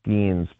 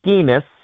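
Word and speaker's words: Skenes: I can't watch Paul Skeens because every time I watch him, I hear Tausch mispronouncing his name as Paul Skeenis, which rhymes with another word.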